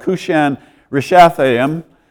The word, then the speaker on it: Cushan-Rishathaim: And so for 8 years, it says, Israel was dominated by a people from the northeast here, whose king is called Cushan-Rishathaim. Good pronunciation there.